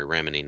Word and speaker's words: Remini: And then yeah, that's the first thing I think I recognize Leah Remini being in. – Leah Remini? – Yeah. I'm sorry, Remini, not Remini. I've got that mispronunciation lodged in my head, and I still haven't got it pried out.